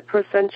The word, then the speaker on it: proscenium: The proscenium arch? – Proscenium. It's proscenium. – Yeah, no, it's not okay. I-C-S-C-I, no sh in there, it's N, proscenium.